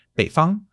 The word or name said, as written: bei3 fang1